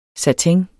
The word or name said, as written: satin